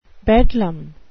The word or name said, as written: bedlam